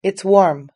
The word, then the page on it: it's warm: British English